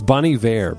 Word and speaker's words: Bon Iver: You're listening to Blood Bank by none other than Bon Iver, or Bon Iver, or Bon Iver is the correct way to say it. I was saying it all the bad ways or wrong ways for those of you who say it that way. It's Bon Iver, by the way, even though it's spelled Bon Iver.